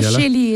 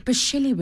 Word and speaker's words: Bascelli: Bascelli is great. Thank you, David. – Does Bascelli work? – Bascelli works for me.